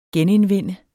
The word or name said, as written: genindvinde